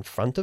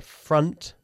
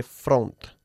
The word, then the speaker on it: front: Ten cuidado con la pronunciación de la palabra front, ya que no se dice front. Front. – Front. – Front. Bajando la mandíbula hacia abajo. Front.